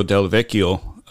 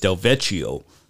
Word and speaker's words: Del Vecchio: And I had to ask him the correct pronunciation because he says a lot of people see his last name and they think it's Del Vecchio, but it's Del Vecchio.